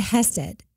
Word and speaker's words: hesed: The closest word that they've come to be able to describe it, and it probably doesn't even come close even still, is the word hesed, and it's spelled H-E-S-E-D.